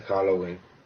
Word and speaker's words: Halloween: Feliz, feliz Jaguelin, ¿cómo se dice? – Feliz Halloween.